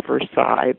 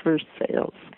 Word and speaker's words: Versailles: It's spelled like Versailles, but it's pronounced Versailles, believe it or not.